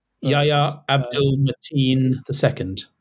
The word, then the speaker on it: Yahya Abdul-Mateen II: Yahya Abdul-Mateen II.